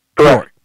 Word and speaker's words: Bjork: It's it's B-J-O-R-K, pronounced Bjork. Bjork. – Correct.